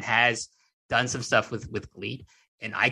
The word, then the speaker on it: Gleat: And like, he's full on doing stuff for Gleat. Not great. Yeah, it's spelled G-L-E-A-T. I know W, but like, it's, you know, L's and R's and mix. And it's like, I know, I fucking lived there for 10 years. Yeah, I know. But listen, they're going to spell it with an L.